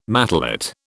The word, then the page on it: matelot: British English